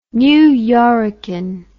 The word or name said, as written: New Yorrican